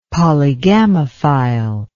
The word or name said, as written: polygamophile